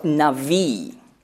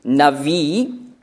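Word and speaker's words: Navi (נביא): Im Hebräischen, im Alten Testament, finden wir das Wort Navi, also BH geschrieben, das spricht man weich aus als W, Navi.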